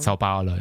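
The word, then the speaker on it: São Paulo: Sao Paulo, ja.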